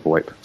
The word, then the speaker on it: VoIP: Sometimes it's referred to by the phrase Voice Over Internet Protocol, which is given the acronym VOIP, sometimes pronounced VoIP.